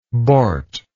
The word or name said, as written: borty